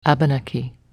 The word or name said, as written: Abenaki